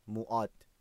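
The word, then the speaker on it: موا (뭐): دقت کنید که ما خالی تلفظ نمیشه، یعنی لبا قنچه میشه موا موا با ما ما ما، یه ذره باید قنچه باشه که با یه کلمه دیگه اشتباه نشه.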